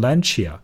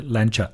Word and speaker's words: Lancia: 但是如果你单纯从一个英语呃角度来讲呢，就可能会读成 Lancia 或者 Lancia。但是呢，这个词其实是读成 Lancia，Lancia，也就是说它没有一的音在后面，只是一个 r 的音，就是 Lancia。